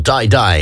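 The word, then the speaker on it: Dai Dai: That's Shakira, her brand new tune called Die Die, and it is the official World Cup theme. It's spelled D-A-I, though, by the way.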